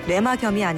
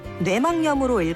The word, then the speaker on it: 뇌막염: 뇌막염이 아니라 뇌막염으로 읽어야 바른 우리말 발음입니다.